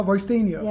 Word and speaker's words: Roystinho: Oh, Roystinho.